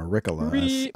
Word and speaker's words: Ricola: Ricolas. Or Ricola, as some absolute fucking moron says. You have to say it right.